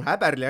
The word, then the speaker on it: Häberli: siis see mees paar päeva tagasi ütles, et kui on ikkagi Sveitsis, tema nime öeldakse Toomas Häberli ääga, ehk siis jääme selle juurde, aga mis kõige olulisem, tegelikult jaanuari alguses siis mees ametlikult välja kuulutati, kuigi kuu aega varemaid sahinad.